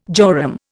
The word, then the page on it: jorum: Indian